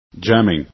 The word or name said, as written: jamming